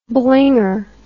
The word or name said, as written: blinger